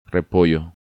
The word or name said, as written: Repollo